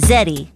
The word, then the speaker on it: Zetty: My name is Zeddy, pronounced with a Z, Z-E-T-T-Y, yeah, that's me.